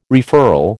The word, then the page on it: referral: American English